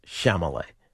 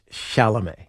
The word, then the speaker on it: Chalamet: Nobody can say it right. Everybody on this station, except for me and Sean, says Chalamet. – Are you sure? – It's Chalamet. – Oh, okay. I don't know. I pronounce every name wrong. Gio was pointing it out this morning that it has Met at the end.